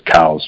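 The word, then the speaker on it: Coues: the families always pronounce their last name as cows, just like a bovine. So I would much rather pronounce it coos because I,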